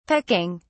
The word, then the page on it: pecking: British English